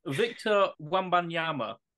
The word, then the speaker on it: Victor Wembanyama: Victor Wembanyama. – Oh, it's a good pronunciation as well. What have you gone for, my friend? – Victor Wembanyama.